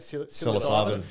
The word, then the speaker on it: psilocybin: they've been using magic mushrooms or how do you say it? – Psilocybin.